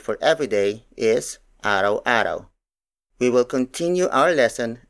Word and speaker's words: araw-araw: For example, araw-araw. Araw-araw. Araw-araw. Araw-araw is spelled as A-R-A-W hyphen A-R-A-W.